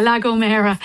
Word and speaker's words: La Gomera: Yeah, La Gomera.